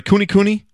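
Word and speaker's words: Kunekune: Hanging in this small room, this cold room, are whole, butchered Berkshire, Duroc, and Coon Cooney pigs. Are they Cooney Cooney? Yeah, Cooney Cooney pigs.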